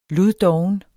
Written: luddoven

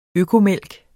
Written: økomælk

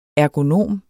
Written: ergonom

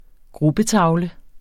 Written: gruppetavle

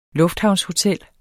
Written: lufthavnshotel

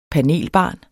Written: panelbarn

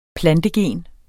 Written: plantegen

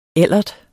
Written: ellert

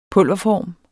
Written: pulverform

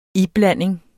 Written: iblanding

